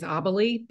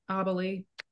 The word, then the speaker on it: Abelie: My last name is Abelie. People struggle to pronounce it. So just wanted to say it for everyone who was ever curious. So kind of sounds like I believe, but Abelie.